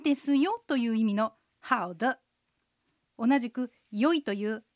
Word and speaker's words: 好的: で、これもアクセントはハオが賛成、ドが形成です。さっきのハオマのマに比べるとドはちょっと低めに発音します。では練習してみましょう。 – 好的。